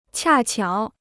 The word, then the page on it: 恰巧: qià qiǎo